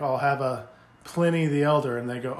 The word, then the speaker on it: Pliny: And they're like, well, the owner of the company calls it Pliny, and he's the one that makes it. So, yeah, we say Pliny.